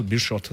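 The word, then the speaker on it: Beerschot: How do you pronounce it? Germinal? Germinal Beer Shop Antwerp in Belgium? Germinal? – I think it's Germinal Beer Shot. – Germinal Beer Shot.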